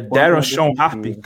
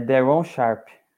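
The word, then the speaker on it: Day'Ron Sharpe: É Daron Sean Harp que se pronuncia? Daron Sean Harp? – É Daron Sharp.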